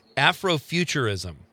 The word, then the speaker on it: Afrofuturism: Tell me if I'm saying it right. Afrofuturism. – Yes, you are saying it absolutely right.